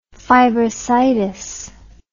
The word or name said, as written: fibrositic